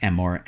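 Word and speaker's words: emor (אמור): Either way, it's pronounced emor and means the same thing, to say.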